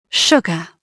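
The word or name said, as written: sugar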